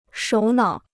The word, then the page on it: 首脑: shǒu nǎo